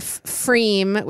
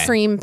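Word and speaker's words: Pfriem: No, of Freem, which is a brewery out of Oregon, I believe. – How do you spell that? – It's P-F-R-I-E-M. – Okay. – Freem Pilsner, which they used to only have in bottles.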